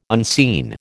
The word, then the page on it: unseen: American English